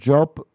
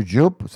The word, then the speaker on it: Jupe: Ese niño, el que hace de su hija, de su hijo, perdón, que se llama Noah Job, o Job, se escribe Jupe, J-U-P-E.